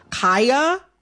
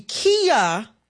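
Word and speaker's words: Khia: I am going to, in this conversation, pronounce Kaya, the rapper, with the long I. Kaya, okay? My neck, my back, for those who need a refresher. Kaya, okay? Mm-hmm. The Kia Asylum is a mispronunciation of her name.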